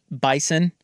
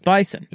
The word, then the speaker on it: Bison: It's Bison. – Yeah. – But he was saying Bison. Well, come to find out, at North Dakota State, they pronounce it Bison. They pronounce it with a Z.